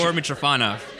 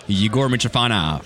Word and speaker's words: Yegor Mytrofanov: Yegor Mytrofanov. – So hello. – Pull that mic right into your face. – Yegor Mytrofanov.